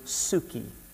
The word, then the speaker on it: psuche: First of all, the word or soul comes from the word psuche. Psuche. It's spelled P-S-U-C-H-E.